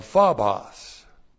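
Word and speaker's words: Phobos (φόβος): This is the dative, P-H-O-B, long O. That's the dative of phobos. Phobos, which is P-H-O-B-O-S. And this is the genitive of theos, genitive of theos, and that's God.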